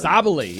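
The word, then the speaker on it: Sabally: Sabali, yeah.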